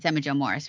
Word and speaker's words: Emma Jo Morris: And you know, Emma Jo Morris was, I said her name right. It's Emma Jo Morris, right? Yeah. – Yes. Yeah.